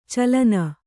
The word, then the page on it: ಚಲನ: calana